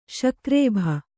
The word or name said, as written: ಸಗರ್ಭ